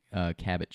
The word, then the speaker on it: Cabot: Gary said, Cabot cheese, right.